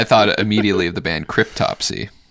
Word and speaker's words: Cryptopsy: Oh, is that how you say it? Cryptopsy? – Yeah. What did you think it was? – I don't know what I thought. That doesn't sound right, though. – Cryptopsy. I mean... – Cryptopsy, because there's a P in it. – Cryptopsy. – It's like cryptopsy. Autopsy from a crypt.